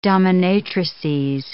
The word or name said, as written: dominatrices